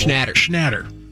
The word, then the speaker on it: Schnatter: Chairman of Papa John's, John Schlatter. That can't be how it's pronounced. Schlatter. That's how it's spelled. This guy's in trouble. – Schnatter. – There we go.